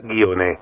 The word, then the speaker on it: Ghione: Acá el otro día, la semana pasada, eh, charlamos con Clara García, decía guione. Otras personas dicen guiones.